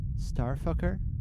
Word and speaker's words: STRFKR: After that, we had Work, Smoothly Lifetime Peace by Starfucker. But you spell it as S-T-R-F-K-R.